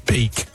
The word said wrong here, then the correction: Van Der Beek: Right person and then pronounce the name wrong. – James Van Der Beek.